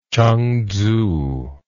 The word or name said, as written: chengtsu